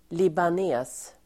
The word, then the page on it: libanes: [liban'e:s]